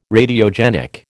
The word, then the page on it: Radiogenic: American English